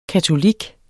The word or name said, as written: katolik